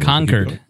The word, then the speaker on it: Concord: You pronounced it wrong. – Concord is how they say it.